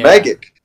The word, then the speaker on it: Majik: Is it spelled weird? – Huh? Yes, it's spelled M-A-J-I-K. – Oh, I was thinking there was going to be a K, but yeah, Magic.